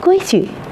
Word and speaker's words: 规矩: 在这个词语中，矩读作轻声，规矩，它指一定的标准、法则和习惯。